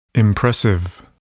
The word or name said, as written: impressive